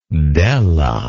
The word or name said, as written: della